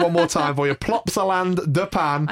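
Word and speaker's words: Plopsaland De Panne: We're now going to move to Plopsaland de Pan. Yes, I did say that right. – I already love the name of this park in Belgium. We're going to talk about Plopsaland de Pan.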